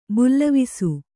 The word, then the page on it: ಭುಲ್ಲವಿಸು: bhullavisu